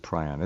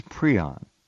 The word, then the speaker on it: prion: She says prion, which is the... There's a minority of people who pronounce it prion. It's prion. That's what she's saying.